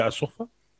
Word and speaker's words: Asofa: And then NRL, so Nelson Asofa Solomoni here, Asofa. It's actually pronounced Asofa. So there's like a macron Asofa over the A.